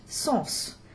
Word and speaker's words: sens: But if it has an article, like le, or ce, c'est, or a possessive article, like here, nos, then the S will be pronounced, sens, and it'll stay an S sound even when followed by a vowel in the next word, as we have here, sens s'extasier.